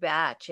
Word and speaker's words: Bach: They pronounce it Bach in England, but we call it Bach here.